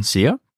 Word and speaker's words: Sia: You have a very close and strong relationship with your mother, Sia. Is that correct pronunciation? – It is correct, yeah.